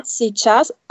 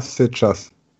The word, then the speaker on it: сейчас: Hier schreibt man zum Beispiel сейчас, я сейчас. Mit diesem I, beziehungsweise diesem umgedrehten M, N. Und obendrauf dieses umgedrehte Dach. – Ja, сейчас, aber man spricht es nicht aus. – Was spricht man nicht aus? – Dieses I.